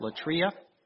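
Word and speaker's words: latreia: It's spelled L-A-T-R-E-I-A. L-E-T-R-E-I-A. It's pronounced latria.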